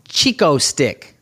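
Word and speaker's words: Chick-O-Stick: Chico stick. – Is that how you say it? – Yeah. You never heard of Chico stick? – No.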